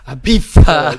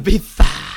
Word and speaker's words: Ibiza: How do you say it? Ibiza. – Ibiza. – Ibiza. – Got to spit over the microphone. – Ibiza.